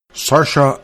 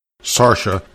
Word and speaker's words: Saoirse: and which I pronounce Sarsha Undaban. Now, he tells me that my pronunciation of the word S-A-O-I-R-S-E is incorrect, and it should be Saoirse or Saoirse or Saoirse, but never Sarsha. Well,